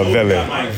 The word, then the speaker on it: Vele: Is it Vele or Vele? – It's a Vele. – Vele. – Yeah, Vele. – So not V-A-Y.